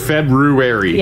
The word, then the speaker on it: February: It's that R. – Yeah. It's the R. – February. – Yeah. I mean, it's pretty, you know, everybody should be able to spell it. If you remember the R.